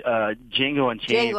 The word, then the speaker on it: Django Unchained: Django Unchained.